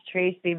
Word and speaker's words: Tracy: My name is Tracy. My company's name is Trazy. It rhymes. It was sort of a happy accident that ends up being easy to remember.